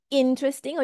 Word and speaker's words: interesting: You might like to say interesting or you might like to say interesting, right? But whatever it is, it's the first syllable stress, okay?